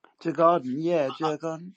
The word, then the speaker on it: Djurgården: Is it Djurgården? – Djurgården.